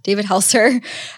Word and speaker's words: David Helser: I listened to a podcast or sermon from David Helser. Am I saying that right? Yeah, David Helser.